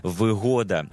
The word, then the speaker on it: вигода: Це трискладовий іменник із наголосом на другому складі. У відмінкових формах наголос зберігається у тому самому складі, що і у вихідній формі, наприклад, вигода, вигоди, вигодою тощо.